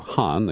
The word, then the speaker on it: Khan: Khan, or more appropriately, they would say in Pakistan, A.Q. Khan, the K is silent, became the father of the Pakistani nuclear weapon.